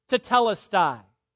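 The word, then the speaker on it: tetelestai (τετέλεσται): It was a Greek word pronounced tetelestai. Tetelestai.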